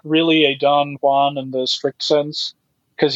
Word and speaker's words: Don Juan: But I would go with Don Juan, which that's how he pronounced it, not Don Juan. It was Don Juan, and you have to say it that way for the rhymes to work.